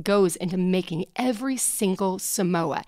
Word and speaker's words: Samoa: Samoas also, little known fact, if you think about Samoa, it happens to rhyme with Jason Momoa.